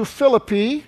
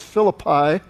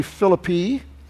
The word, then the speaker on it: Philippi: So he decides then instead of taking shipping over, he's going to go back up through Macedonia, up to Philippi, and that's the way it's pronounced. We call it Philippi here in the States, but the city is literally Philippi.